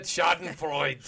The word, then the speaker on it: Schadenfreude: The German term is, I don't know if I'm going to pronounce this right, but Schadenfreude. – Yeah, that's it, Schadenfreude.